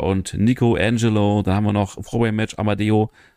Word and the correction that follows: Amedeo: Nee, Amedeo, wo wir richtig aussprechen, besiegt Fast Time Mudo, Joseph Alexander und Starboy Charlie.